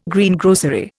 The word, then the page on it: Greengrocery: Indian